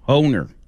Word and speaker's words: Hohner: His name was Evan Hohner. – Never heard of him. – It rhymes with boner, and we were making such a 12-year-old.